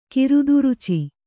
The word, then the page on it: ಕಿರುದುರುಚಿ: kiruduruci